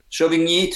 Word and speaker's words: Chauvigny: 217.5 kilometers, big long day from Chauvigny to Saran. Did I pronounce those right? – Chauvigny to Saran. – There we go.